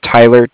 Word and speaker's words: Tyler: It's spelled C-A-R-D-I-F-F. – Okay. – My name is Tyler, T-Y-L-E-R.